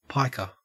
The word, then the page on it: piker: Australian English